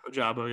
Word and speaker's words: Ojabo: Yeah, Ojabu, yeah.